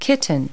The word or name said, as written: kitten